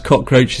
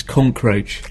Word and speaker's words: cockroach: the fact you just pronounce cockroach is conkroach.